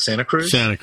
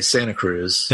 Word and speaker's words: Santa Cruz: Or as Californians tend to say, Santa Cruz, like Santa Claus. – Santa Cruz. – Yeah. But I think the correct pronunciation is more like Santa Cruz or something like that.